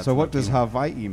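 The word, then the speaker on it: Hawaii (Hawaiʻi): It's called an Okina, so it's actually Hawaii. So you actually pause between the I's. That's the proper way to say it.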